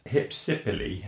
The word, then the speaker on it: Hypsipyle: Now, I'm going to get the pronunciation wrong because it's all Greek, but you're performing as Hypsipyle. – Very good. Spot on.